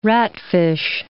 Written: ratfish

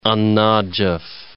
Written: An Najaf

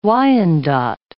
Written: Wyandot

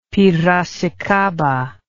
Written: piracicaba

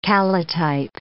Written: calotype

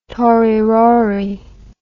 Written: tory rory

